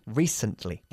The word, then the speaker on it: recently: Recently o recently. – Recently. – Enfatizando la primera sílaba.